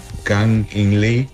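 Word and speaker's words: Kang-in Lee: Ah, espera, a ver si me recuerdo pronunciar su nombre correcto. Se llama Kang In-Lee.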